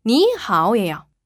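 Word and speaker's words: 你好: 삼삼은 구가 아니라 삼삼은 이삼이다. 그러니까 이삼이 뭐냐면 앞에 성조를 이성으로 발음한다. 근데 너무 헷갈리시면 그냥 니하오 이 음을 외워주시면 됩니다.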